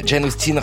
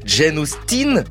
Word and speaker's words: Jane Austen: Jane Austen comme 10, 10 en anglais, voilà, c'est la prononciation anglaise. Mais Jane Austen bénéficie d'une prononciation française. Autrice de la fin du 18e siècle, du début du 19e siècle, c'est un peu comme le roi du Royaume-Uni, Guillaume IV au 19e siècle. Personne en France ne l'appelle William the Fourth. C'est une prononciation française, alors Jane Austen ou Jane Austen, passion Jane.